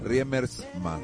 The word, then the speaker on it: Riemersma: Hay una conmoción por la muerte del polaco Riemers. Se pronuncia en realidad, lo voy a describir así para que no hace. Riemersma, ahí está.